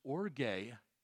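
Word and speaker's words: orge (ὀργή): In the original Greek, it is pronounced orge, but it is specifically mentioned in the book of Revelation as God's displeasure and wrath revealed against unbelieving, Christ-rejecting mankind.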